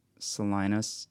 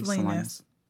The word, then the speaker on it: Salinas: Um, so we have there was Sergio Harris, 38 of North Highlands, De'vazia Turner, 29 of Carmichael, Joshua Hoye Lucchesi, 32 of Salinas. Did I pronounce that correctly? – Salinas.